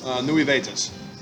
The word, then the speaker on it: Nuevitas: And the orange is the Naivas? – Nuevitas. – Nuevitas, yeah. Which is, I guess, it's like a little town in Cuba.